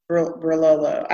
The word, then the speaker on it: Barolo: I said that wrong. Barolo. I say it wrong every single time.